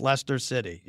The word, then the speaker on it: Leicester: You said Leicester or something like that, but it's Leicester City.